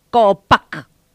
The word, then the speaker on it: Copac: Vamos a decirlo, por ejemplo, ahora las palabras que nosotros manejamos, que en este caso es árbol, en Mocoy sería, ¿cómo pronuncio eso? – Copac. – Copac. Dije bien. – Sí, sonó, sí. Y sonó contundente, ¿sí? – Sí.